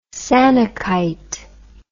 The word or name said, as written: sanukite